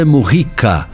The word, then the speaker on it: Mujica: Se pronuncia José Mujica, o ex-presidente do Uruguai, ou José Mujica, que seria a pronúncia correta, porque ele é, a língua espanhola exige esse tipo de pronúncia, mas isso ainda não foi uniformizado no Brasil.